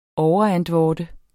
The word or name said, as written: overantvorde